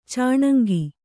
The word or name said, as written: ಚಣಂಗಿ